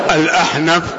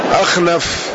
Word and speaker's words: الأحنف: طيب، الأحنف بالحاء المهملة والنون معروف، وبالخاء المعجمة يأتي بالخاء المعجمة أخنف.